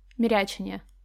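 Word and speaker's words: мерячение: На я ударение, если что. Мирячение.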